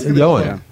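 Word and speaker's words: Ioan: But his first name is pronounced Johan.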